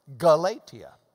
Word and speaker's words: Galatia: It's that hard C, hard K, hard G sound. Galatia.